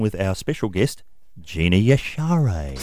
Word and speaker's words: Yashere: Gina Yashere is the correct pronunciation of our guest, Dave.